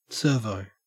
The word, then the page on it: servo: Australian English